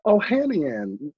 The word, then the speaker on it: Ohanian: Ohanian. – Oh, Ohanian.